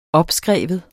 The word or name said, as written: opskrive